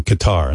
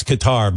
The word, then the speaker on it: Qatar: And then he doubles back to Qatar and then comes back to the United States. – Iran. – He likes Qatar because it rhymes with guitar and he's a guitar player.